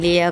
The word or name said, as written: lia